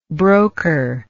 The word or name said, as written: brokership